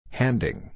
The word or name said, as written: handing